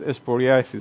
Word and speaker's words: psoriasis: A person like me, I suffer from a condition known as psoriasis. Is that the proper pronunciation? Psoriasis. Thank you.